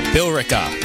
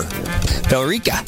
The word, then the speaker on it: Billerica: How do you pronounce that? There's an E in there. – Billerica. – Billerica, maybe? – Billerica. – Oh, that makes sense. Just leave out the E.